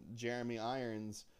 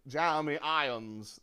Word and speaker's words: Jeremy Irons: there's that scene where it shows Jeremy Irons, where he goes... – No, wait, you said it wrong. – Huh? – You said it wrong. – Jeremy Irons. – There you go.